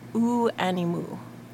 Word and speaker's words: Aux Animaux: Well, next up, I have a song for you by Ooh Animu. I believe that's the correct pronunciation. I asked my friend in France if that's how you pronounce it. It's French. A-U-X-A-N-I-M-A-U-X.